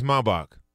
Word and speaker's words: Maybach: What is the Mercedes Maybach? – That is correct. – Yes. – And extra bonus point for correct pronunciation.